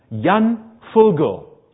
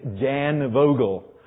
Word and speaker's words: Jan Vogel: Well, there was a Dutchman, Jan Vogel, or in the United States, Jan Vogel. We'll call him on his American pronunciation.